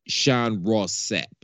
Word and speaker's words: Sean Ross Sapp: Now, to be fair, the reason that this conversation is even a topic because Sean Ross Sapp, I'm believing I'm saying that right. Am I saying that correctly? Somebody yep. – Just at least nod your head. – Yes, sure you're right.